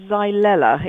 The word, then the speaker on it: Xylella: And well, I pronounce it Xylella. It's spelled X-Y-L-E-L-L-A, but I'm sure your pronunciation is fine as well, Peter.